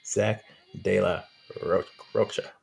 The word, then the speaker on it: Zach de la Rocha: Zach De La Rocha.